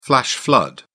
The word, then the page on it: flash flood: British English